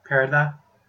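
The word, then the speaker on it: Parado: No, it's like Paradox without the X, pretty much. – Parada?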